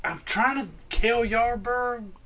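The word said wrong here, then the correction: Cale Yarborough: Kel Yarborough.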